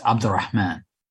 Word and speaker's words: Abdulrehman: It was close. Last name is pronounced Abdulrehman.